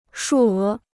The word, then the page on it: 数额: shù é